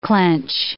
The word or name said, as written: clench